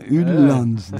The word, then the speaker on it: Jyllands: Gene Danimarka ve üstelik bu karikatürün yayınlandığı gazete Jyllands Posten, aynı gazete, sabık aynı Jyllands diye okunuyor galiba. – Evet.